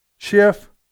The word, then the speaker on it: Schiff: If that's what you want to remove him over, then you know what, Nancy Pelosi and Adam, how do you pronounce his last name? – Schiff.